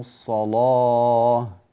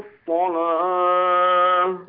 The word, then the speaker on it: الصلاة: في كلمة الصلاة بين تفخيم اللام أكثر، انطقها لو تكرمت، قل وأقاموا الصلاة. – وأقاموا الصلاة، لا هذا التفخيم غير كافٍ، قل الله.